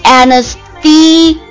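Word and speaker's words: anaesthesia: 好，我们来接着看，刚刚讲是它的动词，对不对？那我们看一下名词，名词的话就是发音上面有点变化，发音上面它变成了 anesthesia 或者 anesthesia。好，后面的这个 A-N-A-E-S-T-H-E 都是不变的，它只是把后面变成了 Asia，亚洲的那个单词 S-I-A 加上了一个，所以它的念法有两种，一个是 ZIA，一个是 ZIA， Anesthesia Anesthesia 或者 Anesthesia Anesthesia。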